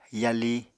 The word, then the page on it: yali: Apatani